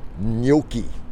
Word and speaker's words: gnocchi: And apparently I forgot to tweak this week's delivery because it included a meal with, try and pronounce this right, gnocchi. G-N-O-C-C-H-I, gnocchi.